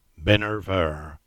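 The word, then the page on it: Great Binner: Cornish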